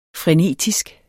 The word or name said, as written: frenetisk